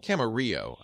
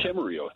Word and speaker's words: Camarillo: Camarillo, I should say. – Yeah. Camarillo, that's right, yeah. It gets mispronounced all the time.